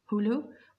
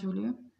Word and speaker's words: Jolo: A native of the southern Philippine island of Hulu, which is spelled J-O-L-O, but it's Mount Hulu.